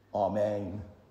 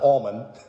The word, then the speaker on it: Amen: A-M-E-N. Amen. Amen. Amen. Right? Not almond. Amen.